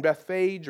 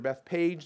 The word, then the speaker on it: Bethphage: Specifically, they're near these two little villages, small villages named Bethany and Bethphage, or Bethpage, depending on how you want to pronounce that.